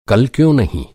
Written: क्यों

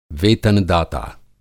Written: वेतन-दाता

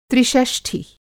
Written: त्रिषष्ठी

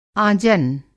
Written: आँजन

